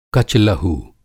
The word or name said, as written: कच-लहू